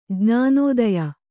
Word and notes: ಜ್ಞಾನೋದಯ: Kannada

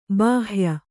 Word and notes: ಬಾಹ್ಯ: Kannada; bāhya

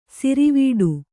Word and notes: ಸರವಟ್ಟಿ: saravaṭṭi

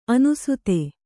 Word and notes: ಅನಾಸ್ಥೆ: anāsthe

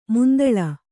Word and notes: ಮಂತುಳ್ಳಿ: mantuḷḷi

